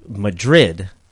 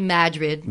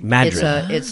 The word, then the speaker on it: Madrid: And you corrected me earlier because I said it was Madrid and my pronunciation was incorrect. So what is the correct pronunciation? – It's actually Madrid. – Madrid.